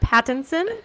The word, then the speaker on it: Pattinson: Um, Robert Pattinson.